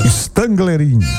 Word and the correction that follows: Stangerlin: Acho que tá tudo errado aqui a minha pronúncia aqui. Stangerlin. É, agora tá certo.